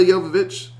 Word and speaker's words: Jovovich: So uh, this film is about uh, Milla Jovovich.